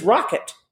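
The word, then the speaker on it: Roquat: And he said, well, you know how to pronounce the Gnome King's original name, right? And we said, no, D, how do we do it? And he said, his name is Rocket, Rocket the Red.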